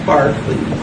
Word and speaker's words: Berkeley: D-E-R-K-E-L-E-Y. Three E's. Barkley.